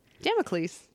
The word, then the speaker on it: Damocles: Damocles.